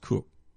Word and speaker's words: Koch: It's spelled K-O-C-H, but it's pronounced Cook.